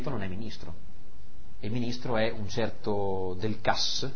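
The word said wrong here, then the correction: Delcassé: qualcosa del genere, Del Cassé, non avevo visto l'accento nella traduzione inglese, un certo Del Cassé, che invece è un anglofilo, no?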